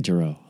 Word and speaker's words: Jero: I say Jero, like Al Jero.